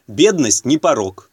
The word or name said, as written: бедность не порок